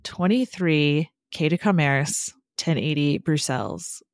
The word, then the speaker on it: Bruxelles: which was poorly pronounced by me, but I did try. – The only thing I would add to that is I think it's Bruxelles. And I know that only because that's how it's said in Arabic.